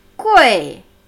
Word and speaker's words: 贵: Nicht ui am Ende, Freunde. Es ist unhöflich und nicht chinesisch, sondern Gui. Tja, die Betonung liegt hier auf U, aber niemand hat das Ego von E aufgehoben.